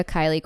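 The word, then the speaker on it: Kiley: At Maria Kylie Crawford, and it's K-I-L-E-Y, not like Kylie Jenner.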